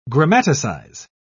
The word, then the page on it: grammaticize: British English